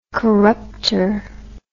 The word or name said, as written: corruptor